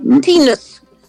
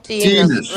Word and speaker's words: Tinus: Tinus. – Kin. – Yeah, like phonetically, I would spell it T-E-E-N-U-S. – Okay, Kins. – Tinus, right.